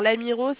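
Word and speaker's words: Lamirault: Alors, l'Amiraux, ça s'écrit LA, M de maman, I, R de Rémi, A, U, L de Laura et T de Thomas.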